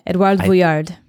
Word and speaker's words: Edouard Vuillard: Edouard Vuillard.